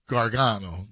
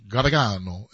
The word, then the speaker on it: Gargano: It's supposed to be, like, the old world says it, like, Gargano. Gargano is how you say it.